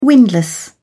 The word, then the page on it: windlass: British English